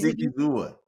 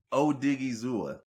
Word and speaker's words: Odigizua: Because Odigizua. – Say it again. – Odigizua. – Odigizua. Okay. – It's exactly how it's spelled. It just looks like those letters shouldn't be together on paper.